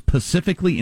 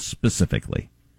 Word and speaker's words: specifically: Mo- uh, top of it is pacifically instead of specifically.